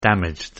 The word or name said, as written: damaged